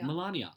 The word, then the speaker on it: Melania: Is that Melania or Melania? – Melania.